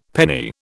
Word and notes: penny: British English

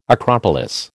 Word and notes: acropolis: American English

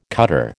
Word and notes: cutter: American English

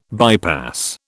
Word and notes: bypass: British English

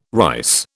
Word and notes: rice: British English

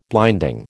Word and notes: blinding: American English

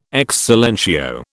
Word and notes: ex silentio: British English

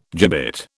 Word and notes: gibbet: British English

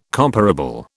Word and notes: comparable: British English

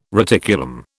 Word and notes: reticulum: British English

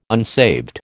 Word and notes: unsaved: American English

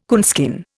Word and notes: coonskin: Indian

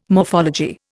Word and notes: morphology: Indian

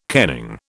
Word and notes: kenning: British English

